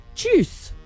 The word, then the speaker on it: Tschüss: Like juice with a ch. Tschüss. – Tschüss. Okay. C-H-U.